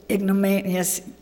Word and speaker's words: ignominious: So he died, and it said he suffered an ignorant, how do you say that? It said ignominious, which means humiliating death.